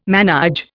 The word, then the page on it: manÊge: Indian